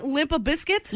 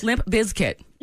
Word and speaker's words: Limp Bizkit: What are you saying? Limp a biscuit? – Limp Bizkit. I'm saying it correctly. – Are you emphasizing the Z? – Yes. – Bizkit? – Yes. Limp Bizkit.